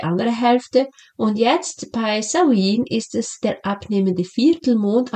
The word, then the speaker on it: Samhain: Das letzte und das erste Jahreskreisfest ist Samhain, geschrieben wird es Samhain, aber ausgesprochen Samhain, und das ist ein Fest der Ahnen und des Übergangs.